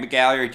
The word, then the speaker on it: McGalliard: My name is Grant McGalliard. You can find me on Twitter at Grant McGalliard. It's spelled exactly like it sounds.